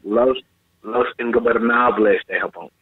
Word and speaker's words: Los Ingobernables de Japón: Listen, Los Ingobernables de Japón. You gotta roll the R's, guys. You got to roll your R's. You got to roll them.